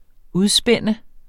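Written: udspænde

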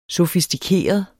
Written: sofistikeret

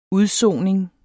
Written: UDSONINGEN

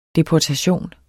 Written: deportation

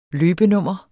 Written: løbenummer

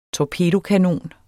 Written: torpedokanon

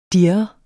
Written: dirre

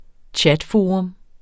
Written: chatforum

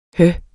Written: hø

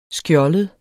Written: skjoldet

